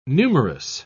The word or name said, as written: numerous